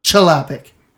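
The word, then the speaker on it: Chlapík: I think it's pronounced Klapik. – Oh. – Chalapik is how they say it. – Well, I think it's actually pronounced Klapik. – Is it? – Okay. Properly, but everyone says it's Chalapik, so.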